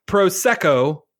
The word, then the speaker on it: Prosecco: And for the record, Prosecco is a hard C, not a CH sound.